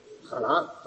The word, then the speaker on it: jalar: Jalar, claro, jalar, sí, sí, sí. Pero depende, porque esa H en Andalucía Occidental se pronuncia, es como la de jamón o la de hacha.